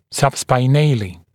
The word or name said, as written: subspinale